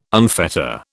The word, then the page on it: unfetter: British English